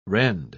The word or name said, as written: rend